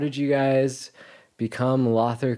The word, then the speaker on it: Lother: Like loafer, Lother. – Yep. That's a yes.